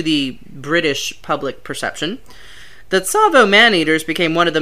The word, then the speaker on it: Tsavo: Tsavo River. It's spelled T-S-A-V-O, so it's sort of a ts sound.